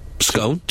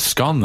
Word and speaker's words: scone: So 51% pronounce it scone. – Bone. That's scone then, bone. – Isn't it? It's rhyme with gone.